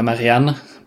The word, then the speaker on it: Marianne: Okay, Marianne ist, oder Marianne, besser gesagt, ich habe das sehr deutsch ausgesprochen gerade, ist Malerin und sie bekommt den Auftrag, Eloise zu malen.